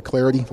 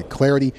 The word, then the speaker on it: Clardy: You can follow me on Twitter at Troy Clarity. Last name is spelled C-L-A-R-D-Y at Troy Clarity.